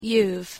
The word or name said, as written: youve